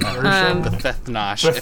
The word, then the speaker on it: Bethethnosh: Bethethnosh, if you want to really... – Bethethnosh. Bethethnosh. The emphasis is on like the second syllable, right? – Bethethnosh.